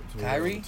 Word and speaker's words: Kyrie: Kyrie? – Kyrie, yeah. – How do you spell it? – K-Y-R-I-E.